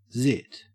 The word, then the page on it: zit: Australian English